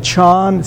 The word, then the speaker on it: Chan: He says, You'll have many experiences when you encounter sitting, again, Chan, C-H-A-N is just the Chinese, which became Zen when Chan, it's just when the character for Chan went to Japan, they pronounced it differently. They call it Zen.